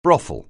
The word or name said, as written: brothel